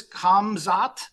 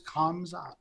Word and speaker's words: Khamzat: You know, it is Khamzat.